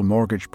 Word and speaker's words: mortgage: It was also the most successful mortgage provider in the region and therefore had a treasure trove of priceless data. – Mortgage. That's a word that a lot of people mispronounce. It's spelt M-O-R-T-G-A-G-E.